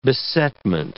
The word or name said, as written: besetment